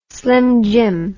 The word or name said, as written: slimjim